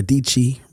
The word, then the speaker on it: Dietschy: So today, I want to first off talk about how I was watching a video on YouTube with Sarah Dietschy, rhymes with Sarah Peachy, and Mark Zuckerberg.